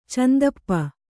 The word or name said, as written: ಚಂದಪ್ಪ